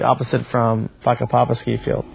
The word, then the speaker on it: Whakapapa: Mount Ruapehu, the name of the volcano is a Maori word, and in Maori, WH is pronounced as an F, so the name of the mountain is called Whakapapa.